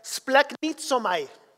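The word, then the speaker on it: splanknitzomai (σπλαγχνίζομαι): Esa palabra compasión en el griego se pronuncia splanknitzomai.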